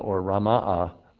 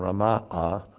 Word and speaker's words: Ramah: And then there's Ramah, which is the correct pronunciation, Ramah, which is where Samuel lived.